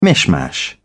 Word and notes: mishmash: British English

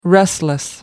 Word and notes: restless: American English

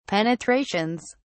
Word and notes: penetrations: British English

